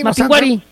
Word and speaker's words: Mapinguari: No, es que no tiene acento en la I. – No tiene acento en la I. – Mapinguari.